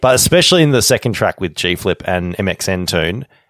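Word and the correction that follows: mxmtoon: MXM Tune.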